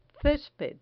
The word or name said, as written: cesped